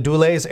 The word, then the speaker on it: Dulles: The Dulles International Airport near D.C. – Oh, it's Dulles, by the way. – Dulles. Thank you, Dulles. I knew I was saying that wrong.